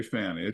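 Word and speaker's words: Fani: I don't know if you guys have heard the Fannie, I guess, however you say her name. – I say Fannie.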